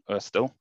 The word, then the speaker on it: Ørsted: based on the Danish scientist Hans Christian Ørsted, which is the correct way to say Ørsted, because he discovered electromagnetism.